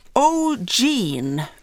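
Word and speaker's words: O'G'3NE: Eh, O'Gene uttalas det trots att det är en siffra där i mitten, är en grupp bestående av tre systrar varav ett tvillingpar.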